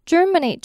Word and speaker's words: germinate: 好，接下来我们看这句话的最后一个字 germinate， germinate， germinate 这个字它虽然是 G-E-R-M-I-N-A-T-E，但是它念成 germinate 比较适合，你就可以想象是 German 加上 ate。